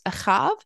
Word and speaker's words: Ahav: And this Talmudic story takes place in the land of Babylon under the reign of Nebuchadnezzar and refers to two false prophets, Ahav and Zedekiah, which are the Hebrew pronunciations of Ahab and Zedekiah, if you've heard those names before, but no relation to anyone in the Bible.